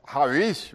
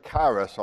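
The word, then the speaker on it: harish (χάρις): That second word is harish, really. We say charis all the time. It's harish, and that's a Greek term.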